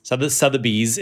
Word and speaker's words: Sotheby's: Sotheby's, Sotheby's is three syllables, Sotheby's. It is not not Sotheby's, correct? Three syllables.